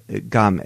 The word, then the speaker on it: kame: Game. It's kind of a G instead of a K. It's again, we have an unaspirated K, so it's not the harsh K. It's a softer K.